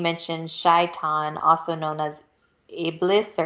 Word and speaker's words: Iblis: Islamic theology is rich in references to Iblis. I believe that's how you pronounce it. – Yeah, the that's exactly.